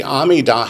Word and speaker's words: Amidah: Spell it A-M-I-D-A-H.